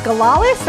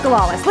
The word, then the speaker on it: Galalis: My last name is Galalis. That's like gala, like a party, and Lisp, like Lisp without a P, but it's pronounced Galalis. Liz Galalis.